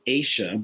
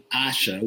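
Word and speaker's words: Aasha: You guys were saying Aisha, but it is actually Asha, which rhymes with Sasha.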